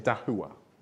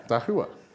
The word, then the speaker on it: Dahua: But you pronounce it Dahua, okay? It's a Chinese brand. See? Dahua.